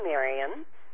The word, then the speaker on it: Marion: It's Marion like librarian, M-A-R-I-O-N. – Oh, that's my legal name, Marion.